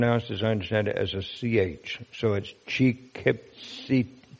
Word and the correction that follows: chikitsitam: chikit, sitam, like that. I'm sorry, what's your question? Oh, oh, oh, oh, okay. All right, slight error here. I don't know whether we have the markers here. Okay, so anyhow, that last word is actually pronounced chikit Chikitsitam. Chikitsitam.